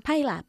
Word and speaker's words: pile up: ¿Cómo se dice choque múltiple? – Peel up. – ¿Y cómo se pronuncia? – Pile up. – A pile up. Pile como una pila, ¿ok? – Ella está esperando un niño.